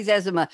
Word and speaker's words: Zezima: Jerry Zezima. It took me years to learn how to pronounce his name, Z-E-Z-I-M-A, Jerry, with a J.